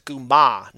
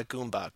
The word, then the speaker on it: Goomba: Nico, I think you're mispronouncing it when you're talking about the Italian plumber. It's a Goomba, not a Goomba.